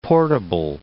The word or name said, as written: portable